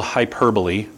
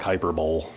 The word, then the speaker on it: hyperbole: Okay, now, this is a good example of what's called hyperbole. Those of you taking notes, kids, it's spelled like hyperbole, but it's hyperbole.